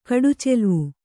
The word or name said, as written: ಕಡುಚೆಲ್ವು